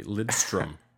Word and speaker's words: Lidström: I'll even say his name properly, Lidström.